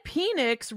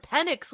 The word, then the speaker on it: Penix: Yeah, because he said Phoenix right away, and then Mm-hmm. specifically intentionally said Penix like seven times after that. Like, so he obviously knows how to say it correctly, which makes me think he's messing with us the first time.